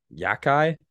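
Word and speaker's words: Xhekaj: In the two fights I've seen this year, because he fought Arbor Wi-Fi from Montreal, I cannot remember how to say his last name. Yakai? – Yeah. – Arbor Jackai.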